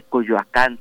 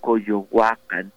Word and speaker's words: Coyoacán: no se dice Coyoacán, se dice Coyohuacán, no se dice Teotihuacán, se dice Teotihuacán, y eh, empezaba a dar cátedra sobre la vieja Ciudad de México.